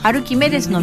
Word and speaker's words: 歩き愛です: 徳島アルキメデスってタイトルがユニークですけど、アルキメデスというのはですね、歩くに振り仮名の木に、アルキメデスの目はですね愛という字ですね。で、歩き愛ですと書いてアルキメデスと読むみたいです。